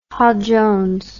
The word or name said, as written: Hall-Jones